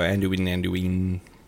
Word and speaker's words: Anduin: Is that how you pronounce that? Anduin? – Yeah, I think so. Anduin.